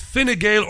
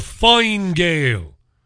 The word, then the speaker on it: Fine Gael: His party is Fine Gael or Fine Gael, depending on your pronunciation.